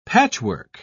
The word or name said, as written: patchwork